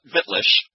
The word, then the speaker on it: Wittlich: You'll know me as Wittlich. People call me Witt for short, but it's actually pronounced with a V because it's a German word, a German town that I adopted, if you will.